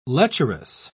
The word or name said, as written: lecherous